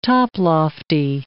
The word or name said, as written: toplofty